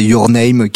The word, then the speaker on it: Your Name: Faut, juste préciser du coup, c'est euh, un film du réalisateur Makoto Shinkai qui, euh, notamment si vous connaissez Your Name, qui a été un grand, grand, grand succès. On, cite régulièrement d'ailleurs ça. Euh, c'est ouais. – Tout à fait, tu le prononces bien mieux que moi.